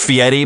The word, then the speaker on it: Fieri: And I will, you know, it's pronounced Fieri, but his real name is Fairy.